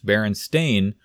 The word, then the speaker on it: Berenstain: And it's also our understanding that it's not only spelled A-I-N, but it's actually pronounced Berenstain based off of Stan and Jan Berenstain.